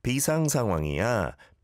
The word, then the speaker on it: 비상상황이야: Yeah, it's good pronunciation for each syllable. We'll just smooth things out and make it a little bit more speedy. Although it wasn't slow, wasn't it? 비상상황이야.